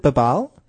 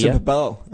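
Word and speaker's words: Babalj: You pronounce it Babal? There's no J on the end. He came out and said, get rid of that J. – Yes, Babal.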